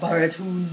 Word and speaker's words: Baratunde: We want to talk about How to Be Black by Baratunde Thurston. – She likes to say his name like that. – Baratunde. Hey, yeah. That's how he phonetically spells it out in the book.